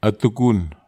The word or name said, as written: atukun